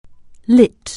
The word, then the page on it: light: anglais